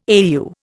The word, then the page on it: AEU: Indian